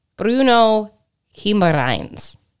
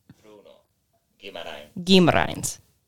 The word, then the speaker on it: Bruno Guimarães: Bruno Guimarães. Bruno Guimarães. – I'm hearing Guimarães. Where's the N coming from? – Guimarães. – Guimarães. Yeah. – Yeah. – Bruno Guimarães.